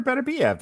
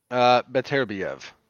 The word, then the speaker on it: Beterbiev: I said Artur Beterbiev. That's how you pronounce it, no? – Beterbiev. – Beterbiev. Better? Better? – Beterbiev. – Right. Beterbiev? – Yeah.